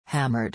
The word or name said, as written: hammered